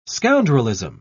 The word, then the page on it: scoundrelism: British English